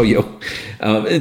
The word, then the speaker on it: Yeoh: And it's got a great, and it has friggin' Michelle Yao in it. Michelle Yeoh.